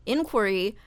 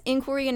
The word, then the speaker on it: inquiry: But for the impeachment inquiry, yes, inquiry and inquiry both correct. It's probably one of those alternate pronunciation things in the dictionary.